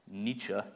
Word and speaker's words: Nietzsche: This is precisely what Friedrich Nietzsche, did I pronounce that right? All right, good, yeah.